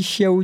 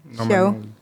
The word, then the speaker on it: Xiao: Et petit truc, je suppose que Xiao Han, c'est écrit X-I-A-O. Oh, oui. Et si je me trompe pas, et je me trompe peut-être, mais je crois qu'on dit Xiao du coup. – Xiao. Ah, j'ai pas, j'ai pas l'accent. Je suis désolée. je vais me faire taper sur euh, les doigts. Bien prononcé pour toutes les prochaines fois où quand tu vois un quelque chose qui vient de Chine avec un X et Xiao en général. – Xiao, comme Xiaomi.